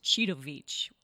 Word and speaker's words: Citovic: And you imagine that you're taking a bag of Cheetos to the beach. And so my last name is Citovic.